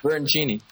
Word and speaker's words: Bertoncini: Bertoncini.